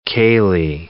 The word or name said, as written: ceilidh